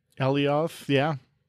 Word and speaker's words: Alioth: Work is still being done in preparation for, am I pronouncing that right? Alioth? – Alioth, yeah.